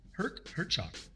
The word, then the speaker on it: Herchok: Okay, and then we had a Dave Herchok. – He played keyboards for a short period of time. – Keyboards and backing vocals. Did I pronounce it right, Herchok? – Yes.